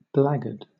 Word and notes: blackguard: British English

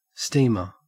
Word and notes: steamer: Australian English

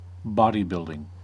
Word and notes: bodybuilding: American English

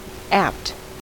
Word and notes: apt: American English

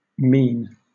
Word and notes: mien: British English

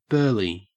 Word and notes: burly: Australian English

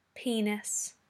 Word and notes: penis: British English

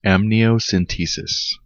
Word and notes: amniocentesis: American English

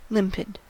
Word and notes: limpid: American English

